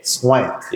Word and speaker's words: Swank: S-W-A-N-K. – Swank.